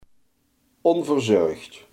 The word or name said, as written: onverzorgd